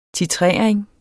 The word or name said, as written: titrering